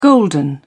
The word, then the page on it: golden: British English